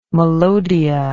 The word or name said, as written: melodia